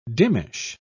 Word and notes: dimmish: British English

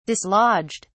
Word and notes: dislodged: British English

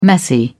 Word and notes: messy: British English

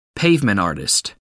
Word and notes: pavement artist: American English

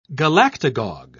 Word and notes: galactagogue: British English

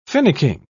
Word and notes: finicking: British English

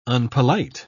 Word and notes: unpolite: British English

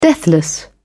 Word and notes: deathless: British English

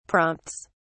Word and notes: prompts: British English

